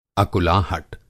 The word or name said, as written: अकुलाहट